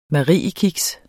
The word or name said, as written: mariekiks